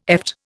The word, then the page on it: eft: Indian